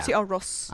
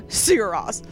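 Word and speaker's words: Sigur Rós: And insisted I pronounce it right. – Sierros. – Every time I jokingly called them Sugar Ray. – I always call them Sigaros. – Sigaros. – And I'm like, I know I'm not saying this right.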